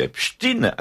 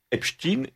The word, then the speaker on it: Epstein: Et tout le monde disait Epstein à New York, obstinément. Parce que c'était l'usage. Alors, ce que cet usage vienne du fait qu'il fallait pas, judaïser la prononciation, c'est possible. Mais en tout cas, Epstein est une prononciation parfaitement américaine qui a été reconnue par tous les médias américains dans les années 90-2000.